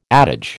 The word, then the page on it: adage: American English